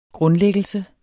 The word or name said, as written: grundlæggelse